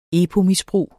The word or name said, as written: epo-misbrug